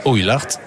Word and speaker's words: Oulart: Ouille comme Jacouille, j'ai bien retenu. – Oui. – Oulart. Ouille comme vous me marchez sur le pied, vous me faites mal.